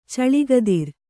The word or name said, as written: ಚಳಿಗದಿರ